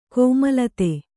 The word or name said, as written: ಕೋಮಲತೆ